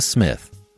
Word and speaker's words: Psmith: And now, Leave It to Smith, The P is Silent, Part 1 of 10, by P.G.